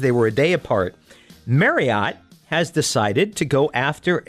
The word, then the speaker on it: Marriott: It's supposed to rhyme with chariot. – So it should be Marriott.